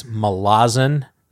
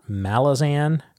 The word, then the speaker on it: Malazan: What I have come to figure out is that it seems like most of the pronunciation for the multi-syllable words tends to fall in the second syllable. That's yes. One thing I've noted as a pattern. It's not perfect, yes. – But it's Malazan, not Malazan.